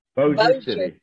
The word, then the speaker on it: Bossier: Bossier City.